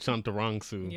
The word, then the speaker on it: Chantarangsu: Chantarangsu.